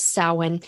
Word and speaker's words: Samhain: And you may, if you're just looking at the spelling on that, it's Samhain, but the pronunciation is Samhain, and it runs from October 31st to November 1st.